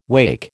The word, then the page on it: wacke: American English